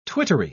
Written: twittery

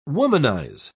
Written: womanize

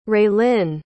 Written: raelynn

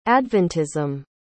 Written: adventism

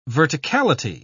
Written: verticality

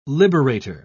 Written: liberator

